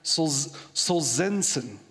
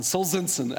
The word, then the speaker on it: Solzhenitsyn: This guy, Alexander Solzhenitsyn, that's how you say it, rolls off the tongue, Solzhenitsyn, Alexander Solzhenitsyn.